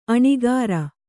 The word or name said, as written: ಅನಕರ